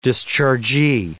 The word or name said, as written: dischargee